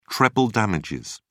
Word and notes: treble damages: British English

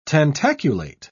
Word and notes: tentaculate: British English

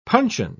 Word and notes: puncheon: British English

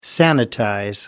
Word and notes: sanitize: American English

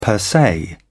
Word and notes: per se: British English

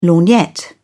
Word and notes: lorgnette: British English

